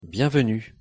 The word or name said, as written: bienvenue